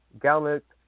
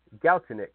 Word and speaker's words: Galchenyuk: Text line, yes, the Alex Galnick, or however you say his name, the Max Domi trade. Yeah, I'll say this. Alex Galchenyuk, I think is how you say his name.